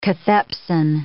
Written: cathepsin

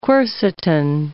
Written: quercetin

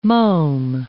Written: mome

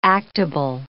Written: actable

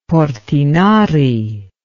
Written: Portinari

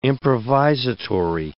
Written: improvisatory

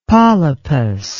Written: polypous

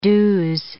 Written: do's

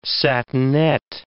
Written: satinet

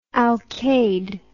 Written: alcade

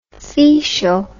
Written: fecial

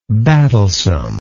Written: battlesome

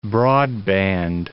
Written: broadband

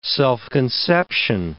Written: self-conception

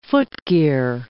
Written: footgear

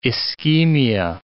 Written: ischemia

